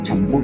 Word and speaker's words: champú: Que es el, el Dorado, el Dorado era un, shampoo, bueno, champú como pronuncian los españoles, ¿no?